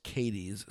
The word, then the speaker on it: Cadiz: So on Saturday, this gentleman in Trigg County, Kentucky, in the city, I believe it's pronounced Cadiz, C-A-D-I-Z.